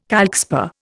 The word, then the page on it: calcspar: Indian